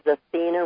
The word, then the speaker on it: Athene: It is Athena Raphael. The first name is spelled A-T-H-E-N-E instead of with an A on the end.